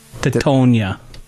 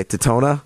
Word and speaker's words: Teutonia: What did I say? Totona? – Yeah, you kind of used Totonia. Yeah. It was pretty damn close. – You were close. – For the record, that's spelled T-E-U-T-O-N-I-A.